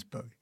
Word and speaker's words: Bowie: Yeah, I think it's established the correct pronunciation is Bowie.